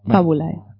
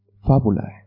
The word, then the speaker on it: Fabulae: Perdón, te voy a corregir la pronunciación latina, ¿vale? Fabulae. – Uy, Fabulae.